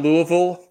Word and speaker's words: Louisville: Are you impressed that I got Louisville pronounced correctly? – I am impressed. I went to school in Ohio for college and learned that I had to start saying Louisville.